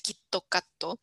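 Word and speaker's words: kitokato (キットカット): lo dan muchas veces a los estudiantes que van a presentar exámenes, porque la forma en la que se pronuncia en japonés es el kitokato, que Quito cat. – suena a kitokatsu, que es como vas a ganar o voy a ganar.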